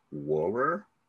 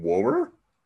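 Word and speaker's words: Wuhrer: Julian Anderson auditioned for the role of Denise, but she lost it to Carrie Warrer. Like, literally, that's how you pronounce her name. Warrer? – Oh.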